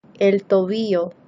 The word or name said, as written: el tobillo